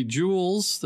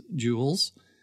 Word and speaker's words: Jules: And at 7 p.m., the movie Jewels, that's spelled J-U-L-E-S, Jewels, rated PG-13, comedy, drama, sci-fi.